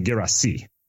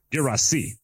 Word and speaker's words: Guirassy: I mean, to be honest, you know, soccer pronunciations get fuzzy, but most commonly Guirassy. He was born in France. He's from Guinea, where French is the language. So Guirassy.